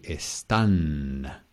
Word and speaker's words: están: Here, the accent is here, están. Accent on the A, están. Okay, and um, estas cataratas están en Sudamérica.